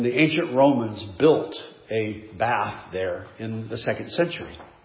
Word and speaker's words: Bath: We went one day to Bath, England, and I was told it's pronounced Bath, Jeff, Bath, not Bath.